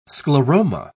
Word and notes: scleroma: British English

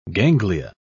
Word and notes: ganglia: British English

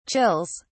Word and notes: chills: British English

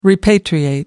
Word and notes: repatriate: American English

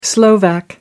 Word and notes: slovak: American English

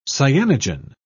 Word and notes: cyanogen: British English

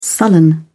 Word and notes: sullen: British English

